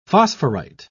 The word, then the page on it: phosphorite: British English